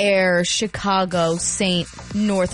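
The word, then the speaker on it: Aire: His name is Air. So if you saw it online, it's A-I-R-E. It's pronounced air, like the air you breathe.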